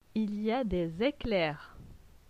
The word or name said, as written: Il y a des eclairs